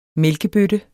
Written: mælkebøtte

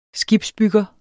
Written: skibsbygger

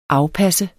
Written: afpasse

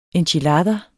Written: enchilada

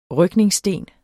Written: rygningssten

